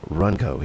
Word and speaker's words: Wronko: Alan Runco, W-R-O-N-K-O is the way you spell it, Runco here, as Alan Runco, Beach Girl.